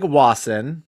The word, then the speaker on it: Wasson: Craig Wasson, am I pronouncing that right? Wasson? – Sure.